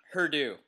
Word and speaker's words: Purdue: It rhymes with hurt you. Purdue. – And that's all we'll say about that. – That's all we'll say about that. It's in West Lafayette. Yeah. It's Purdue.